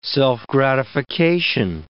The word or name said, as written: self-gratification